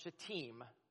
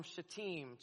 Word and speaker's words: Shittim: Remember your journey from Shittim. That's how that word is pronounced, everybody, okay? Just to make that clear. Remember your journey from Shittim to Gilgal, that you may know the righteous acts of the Lord.